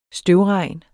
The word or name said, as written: støvregn